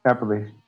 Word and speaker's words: Appalachian: Appalachian.